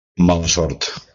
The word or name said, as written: mala sort